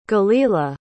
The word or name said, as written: galilah